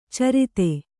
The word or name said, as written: ಛುರಿತ